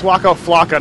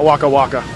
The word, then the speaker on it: Waka Flocka: Wait, it's Waka Flocka, not Waka Flocka.